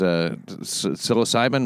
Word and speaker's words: psilocybin: Sort of looked for a solution outside the realm of what's normally, but now by using mushrooms, this is psilocybin mushrooms, or is that how you pronounce that? – Yeah.